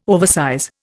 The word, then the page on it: oversize: Indian